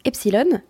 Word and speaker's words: Epsiloon: Je crois que ça se prononce epsilon, mais il y a deux O.